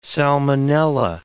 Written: salmonella

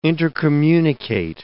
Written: intercommunicate

